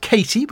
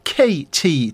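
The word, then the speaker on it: KT: Now, you might think it's pronounced Katie, but it's not. It's pronounced as two letters, K.T.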